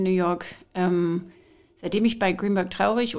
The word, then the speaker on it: Greenberg Traurig: Du bist Arbeitsrechtlerin bei, ich muss das richtig aussprechen, Greenberg Traurig, ist das richtig? – Genau so, ja. Man möchte das Deutsch aussprechen, aber dann klingt es falsch.